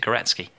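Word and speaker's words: Górecki: And so it's Gorecki, it's spelt, but I've looked it up. Apparently you pronounce it Gorecki. – Ah, okay. – Which reminds me a bit of Wayne Gretzky.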